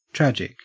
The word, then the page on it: tragic: Australian English